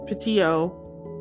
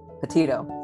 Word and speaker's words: Petito: I pronounce it, Petito. – Petito? – Petito.